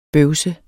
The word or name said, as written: bøvs